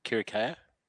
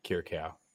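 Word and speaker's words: Kyriacou: Kirikau? – No, Kirikau. Okay. You want to produce a pronounce a name wrong? That's on you. But Steffi K. – Steffi Kirikau.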